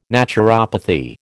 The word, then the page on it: naturopathy: American English